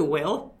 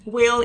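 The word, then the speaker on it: Whil: And going back to Will, I feel like we yeah.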